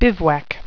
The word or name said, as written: bivouac